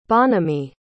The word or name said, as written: Bonamy